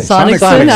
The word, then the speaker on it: Sonnenschein: Sonnenschein, I can never Sonicson. – Say his name. – Sonnenschein, I know.